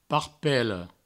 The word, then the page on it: parpela: parpello